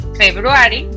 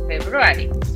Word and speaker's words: February: Cuidado con los acentos donde ponemos la palabra, porque queremos decir February y no es February. February. ¿Vale? Porque queremos poner el acento en el mismo sitio donde está en español. Febrero.